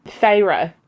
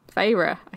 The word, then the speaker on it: Feyre: How do you pronounce her name? – Feyre. – Feyre. Okay. – Feyre? – I think that is actually how you pronounce it.